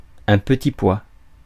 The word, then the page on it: petit pois: /pə.ti pwa/